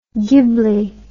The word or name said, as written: gibli